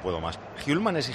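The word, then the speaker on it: Hjulmand: Paquito, por cierto, para pronunciarlo bien, me ha escrito Hjulman. Miguel Ángel de Copenhague, nuestro amigo y oyente fiel, se dice Hjulman. – Hjulman. – Doble L. Hjulman. Vale. Me ha escrito, dice, lo demás está bien, pero este tienes que decirlo Hjulman.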